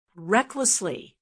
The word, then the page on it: recklessly: American English